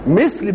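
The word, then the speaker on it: مِثْل: مثل بكسر الميم وسكون الثاء مش كده، ومثل بفتح الميم وفتح الثاء.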